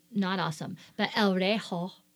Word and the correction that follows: reloj: Relo. – El Relo. Oh, did I spell it wrong here? – Yeah. – R-E-L-O-J. Relo.